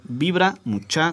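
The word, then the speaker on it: Vibra Muchá: Somos Vibra Mucha con acento en la última A y ahí estamos en todas, en cualquier red social que le busquen, ahí andamos.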